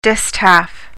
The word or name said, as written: distaff